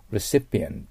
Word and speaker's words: recipient: そしてその次の受け取り人はレシートを受け取る人なんだけど、今度は P を発音します。 recipient です。